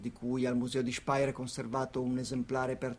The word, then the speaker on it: Speyer: Sì, è una foto che ho scattato io al museo di Speyer, come dice, come ricorda correttamente la pronuncia Mike,